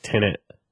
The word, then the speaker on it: Tenet: So I was calling it, it's spelled T-E-N-E-T, which is actually pronounced Tenet. Tenet. Okay. I think it's how you're supposed to say it. – Okay. – I was saying it like Tenet without the N, like Tenet, but it's Tenet.